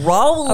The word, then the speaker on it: Aurola: I don't know how to say it, but it's A-U-R-O-L-A, Arola. – Arola.